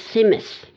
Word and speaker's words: tzimmes: And we also talked about Tzimtzis, and it's spelled T-Z-I-M-M-E-S. – Yes. – Strange spelling to me. – It is. It's Tzimtzis.